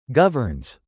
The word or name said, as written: governs